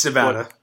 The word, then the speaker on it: Nevada: But, I mean, look, the Nevada, I always forget how to say it, and I was always corrected. – It's Nevada.